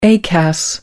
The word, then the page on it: acas: American English